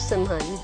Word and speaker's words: Narasimhan: Did I say it right? – Yeah. – I'm yeah. black. – Narasimhan. – Narasimhan.